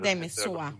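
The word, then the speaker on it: Demeusois: Bueno, es francés, es Demesua, pero da igual. – Demesua, vale. Diego.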